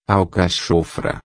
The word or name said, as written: alcachofra